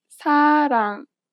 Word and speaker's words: 사랑: This letter is often represented as an R or an L, but you do not pronounce it this way. In fact, there is no equivalent sound in English for it. Listen to a word that uses this letter. – 사랑. 사랑. – It sounds closer to an L than an R in English.